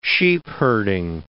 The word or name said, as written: sheepherding